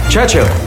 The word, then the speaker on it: Churchill: Churchill, that's how you say it, altogether like that. I'd heard church and hill, but couldn't find either.